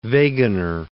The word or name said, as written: Wegener